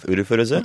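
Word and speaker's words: Urufuruza (ウルフルズ): You know, the funny thing about the, you know, the one that makes you laugh, Urufuruza, mm-hmm. – That's actually just the this is not a joke. That's the Japanese pronunciation of the word wolves.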